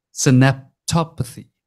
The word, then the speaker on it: synaptopathy: Something you brought up in one of your lectures is called synaptopathy. Synaptopathy. Am I saying that correctly? – Yes.